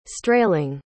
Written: strayling

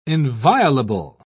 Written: inviolable

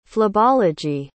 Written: phlebology

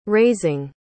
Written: raising

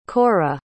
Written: korra

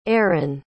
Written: eryn

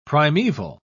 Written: primeval

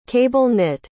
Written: cable-knit

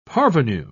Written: parvenu

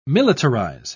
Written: militarize

